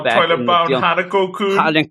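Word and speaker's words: Toilet-Bound Hanako-kun: Oh, Toilet Bound Hanako-kun.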